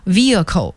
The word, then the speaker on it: vehicle: 那说起方向盘，我们也不得不再去复习一个词叫车辆，机动车，英文叫 vehicles， V-E-H-I-C-L-E， vehicle 机动车。那么这个单词 H 不发音啊，所以它不是要念成 vehicle，而是 vehicle，机动车。